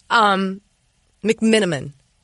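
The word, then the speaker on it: McMenamin: Dave McMinigan. Did I pronounce that? McMinigan. McMiniman? There's no G in it. McMinniman. Okay. McMiniman. Did I say it? Okay, there we go. Third time's a charm, always.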